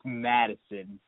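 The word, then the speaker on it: Mattison: And is Mattinson? – Your pronunciations, I believe it's Madison, but you might want to check me on that.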